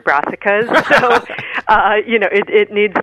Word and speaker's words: Brassicas: Brassicas.